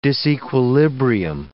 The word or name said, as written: disequilibrium